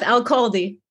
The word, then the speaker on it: Elkhaldy: Close enough. Elkhaldy.